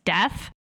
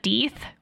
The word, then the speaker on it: Deeth: Like, they are a family that came over with William the Conqueror, and apparently had this thing where the Saxon peasants kept mispronouncing their name as Death instead of Deeth, which is how it was supposed to be pronounced, and so they would get very angry if you mispronounced their name.